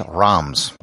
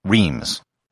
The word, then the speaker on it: Reims: The main city in the Champagne region is Reims, or as it's often pronounced in English, Reims.